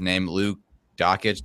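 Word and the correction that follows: Dončić: Dosich. I can't. – Doncic. – Doncic.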